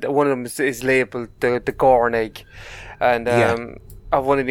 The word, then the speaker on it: Gorn: She noticed the crone egg. Is it how you pronounce it? – The Gorn. – The Gorn egg, that's correct.